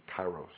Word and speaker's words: kairos: The other one, what I've read is that the way to pronounce it English-wise would be kairos.